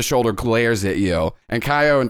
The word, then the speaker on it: Caio: So Tommy's the secretary, Kaio, and that's spelled C A I O, but I looked up the pronunciation, and it's Kaio. I think that's what it is. That's what Google told me.